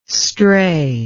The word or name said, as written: stray